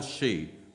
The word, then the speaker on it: Hsu: Al Shee. So his name is spelt HSU. It's pronounced she.